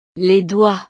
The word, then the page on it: les doigts: frances